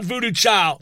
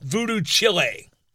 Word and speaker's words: Voodoo Chile: repeatedly pronounced as Voodoo Chile. Not Voodoo Child, Voodoo Chile.